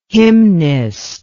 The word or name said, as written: hymnist